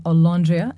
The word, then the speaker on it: Olondria: My guest today is the writer Sofia Samatar, whose first novel, A Stranger in Olondria*.